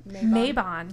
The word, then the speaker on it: Mabon: Also, in this moment, in the books, they call it Mabon, M-A-B-O-N. That's how they say it. – Mabon.